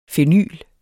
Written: fenyl

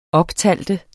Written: optæller

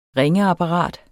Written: ringeapparat

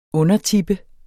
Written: undertippe

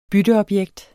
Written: bytteobjekt

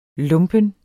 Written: lumpen

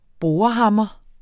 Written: borehammer

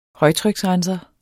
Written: højtryksrenser

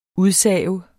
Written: udsave

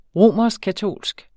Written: romerskkatolsk

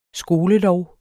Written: skolelov

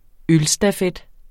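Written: ØLSTAFET

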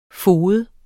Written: fode

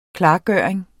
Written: klargøring

